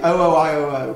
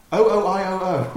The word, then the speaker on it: OOIOO: It is definitely O-O-I-O-O. Because right. If you look on Bandcamp, it actually says, pronounced O-O-I-O-O. – Oh. – E-I-E-I-O.